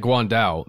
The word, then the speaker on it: Guan Dao: And especially that Guan Dao, that's the weapon, I believe, is how you say that. – That's right.